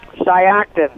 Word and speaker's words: Shiocton: Shiocton.